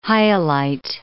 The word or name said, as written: hyalite